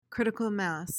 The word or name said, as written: critical mass